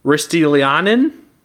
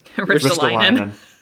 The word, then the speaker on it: Ristolainen: And maybe combine him with, I'm going to butcher the name, Ristolainen? – Ristolainen. – Oh, I was pretty close. – No, you were not.